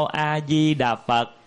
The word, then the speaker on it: A Di Đà Phật: Nam mô A Di Đà Phật thì chữ A mình phải đọc thành chữ A, phải chữ A mà quý vị phát âm thành chữ Á là nó trật. Mô là phải mô, Di Đà Phật rành rẽ rõ ràng.